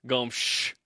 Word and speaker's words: Gomes: Well, the native pronunciation is Gomes. I'm not even kidding. – I know. – Yeah. – I know you're not kidding. – Portuguese. From Portugal.